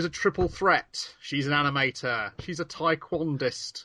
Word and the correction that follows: taekwondoist: Taekwondoist, sorry. I mispronounced my bad mispronunciation.